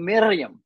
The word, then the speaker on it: Miriam: How many women in the New Testament do you see named Miriam? You got to roll your R's when you do that. Miriam. It's not easy for us Americans to do.